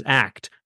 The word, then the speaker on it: ACT: The community has chosen to pronounce it as ACT rather than ACT, which is a bit odd because most other approaches use the letters spelt out.